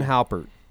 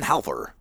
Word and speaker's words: Hafer: Ron Halpert. – Hafer. – Evan Hafer.